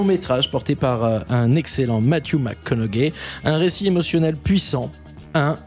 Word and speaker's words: Matthew McConaughey: Alors ça se prononce Matthew McConaughey. – Matthew McConaughey. – Avec son accent du sud des États-Unis.